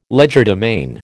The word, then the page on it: legerdemain: American English